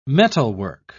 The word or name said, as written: metalwork